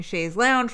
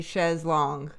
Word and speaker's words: Chaise Longue: Hearing Chaise Longue, which drove me crazy because why are they pronouncing Chaise Lounge wrong? And then I've heard other people pronounce it that way. I think maybe it's a European thing because the French would be Chaise Longue, but whatever.